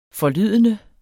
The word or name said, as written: forlydende